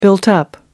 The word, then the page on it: built-up: American English